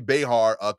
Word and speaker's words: Behar: Joy Behar continues to insist she had a menage a trois with Ghost.